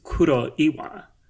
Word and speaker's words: Kuroiwa: So it's, so like the English, I guess, is Kuroiwa. – What? Say that again? – Kuroiwa. – Okay. – I mean, but the Japanese pronunciation is Kuroiwa.